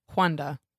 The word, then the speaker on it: Juana: J-U-A-N-A. – Wanda. – But pronounced Wanda.